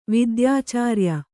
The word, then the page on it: ವಿದ್ಯಾಚಾರ್ಯ: kannada